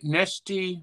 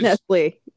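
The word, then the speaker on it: Nestlé: You, you've pronounced it Nesty before, but it's, Nestle, yeah, it's Sorry.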